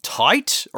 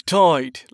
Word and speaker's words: Tide: Captain Morton Tight or Tot? – It's Tide, like Tide, but spelled with a Y.